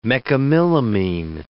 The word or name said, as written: mecamylamine